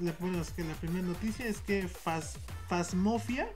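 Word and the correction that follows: Phasmophobia: no sé si así se pronuncia bien o Fasmofobia, ¿no? – Fasmofobia.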